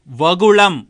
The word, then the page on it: வகுளம்: Tamil